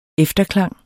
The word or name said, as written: efterklang